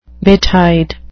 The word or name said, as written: betide